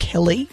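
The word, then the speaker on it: Kelleigh: Him and his wife, I believe her name is pronounced Kelly. Spelled in an interesting way.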